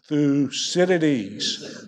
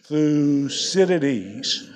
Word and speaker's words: Thucydides: And particularly for our Chinese students or others here, there's generally a problem pronouncing this fellow's name. So in unison on three, we're going to say 1, 2, 3, Thucydides. One more time. 1, 2, 3, Thucydides.